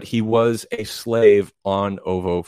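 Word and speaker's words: Oovo: So this ship, quote, the Slave One, was actually a attack ship and a defense ship surrounding the planet of Uvu 4. I think that's how you say it. Ovo. It's O-O-V-O-O.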